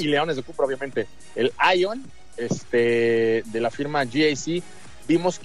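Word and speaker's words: Aion: la pronunciación es con A, Ion, no Lion, Ion de GAC, si no se vaya ahí a prestar a...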